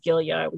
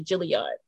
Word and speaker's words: Gilliard: My last name, even though the American pronunciation is Gilliard, we say Gilliard.